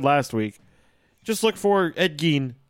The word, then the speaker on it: Ed Gein: I like Ed Gein, because I'm pretty sure that's how it's supposed to be pronounced. – I'm pretty sure that that's correct. I think it's Ed Gein.